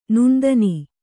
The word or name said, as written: ನುಣ್ದನಿ